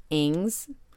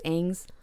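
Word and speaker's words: Agnes: In 1954, Jim and his wife adopted their first child, Ings, A-G-N-E-S. I think it's pronounced Ings, who was part Native American.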